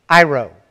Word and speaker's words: airo: That word, airo, is the way it's pronounced.